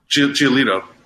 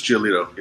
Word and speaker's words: Giolito: You pronounced his name right. Was it Giolito? – Giolito or... – Giolito. Lucas Giolito, yeah.